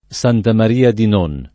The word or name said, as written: Santa Maria di Non